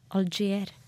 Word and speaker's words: Algier: Her eh, får du eh, Kalexia med Algerie. Og ja, vi kjører. Ja, der fikk du Calexico med Algerie. Eller Algier er det vel. Algier. Jeg tror det er fransk, så jeg uttaler det sånn.